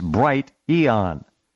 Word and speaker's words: Bright Eon: It's Bright Eon, like Bright Age, a new age of understanding and enlightenment.